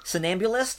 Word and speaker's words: Somnambulist: So the closest they came to this is they introduced a character, I'm going to mispronounce this word, Somnambulist? – Somnambulist, yeah.